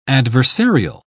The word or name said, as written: adversarial